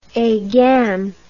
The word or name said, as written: agam